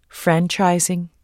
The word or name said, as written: franchising